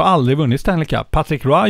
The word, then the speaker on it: Patrick Roy: Patrick Roy. – Ja, bra uttal också. Kanada-uttal.